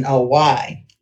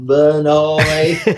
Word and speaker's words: Vannoy: and she spelled it like it's spelled Vannoy, which I have found is really a French name spelled Benoi. – Ah. – So, you know, she was from Lubbock, Texas, and Vannoy came out Vannoy.